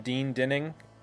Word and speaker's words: Dean Dinning: I'm with Glen Phillips and Dean Dinning.